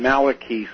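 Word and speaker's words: Malachi: He actually pronounced his name Malachy, so I tend to call him that.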